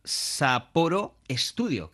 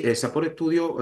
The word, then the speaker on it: Sapporo Studio: Oye, el nuevo Crazy Taxi será una superproducción en la que participa Sapporo Studio. Creo que lo he dicho bien. – Sí, Sapore Studio es una subsidiaria de Sega, su segunda subsidiaria, que está hecha.